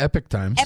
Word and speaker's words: Epoch Times: And then we've got CMAQ from, did we figure out how to pronounce it? Epoch Times? – It's the Epic Times.